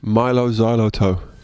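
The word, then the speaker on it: Mylo Xyloto: Milo Xylotoe.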